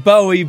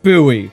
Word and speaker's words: Bowie: It's spelled Bowie, but it's pronounced Bowie.